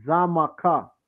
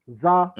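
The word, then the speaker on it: Xaymaca: Because the land, one of the oldest names the land was called was Zamaka. Zamaka. Mm-hmm. You understand? Or it was spelled with an X, and the X used to be pronounced as a Z, okay?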